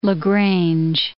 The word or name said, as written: La Grange